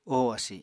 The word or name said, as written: oho asi